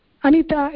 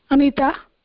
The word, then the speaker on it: Anita: how do you say your name, Bhagini? Anita or something else? – Anita itself, Mahodaya. Anita.